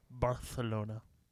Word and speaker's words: Barcelona: That's how you're supposed to pronounce it, with like a TH sound. Barcelona.